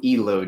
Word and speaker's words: Elo: It's Low Elo Joe. Elo is like a gaming thing.